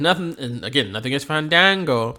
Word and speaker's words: Fandango: It was a grudge because Jericho purposely butchered Fandango's name, which Fandango took offense to for some reason. It's not Fan Booty. Yeah. It's Fandango.